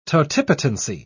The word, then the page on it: totipotency: British English